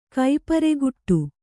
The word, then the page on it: ಕೈಪರೆಗುಟ್ಟು: kannada; kai pareguṭṭu